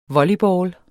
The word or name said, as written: volleyball